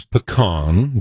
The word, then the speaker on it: pecan: I know the proper Utah pronunciation is pecan, but just to defend here, when Obama said, yes, we can. – Yes, we can. – He didn't say, we can. He said, we can. Pecan.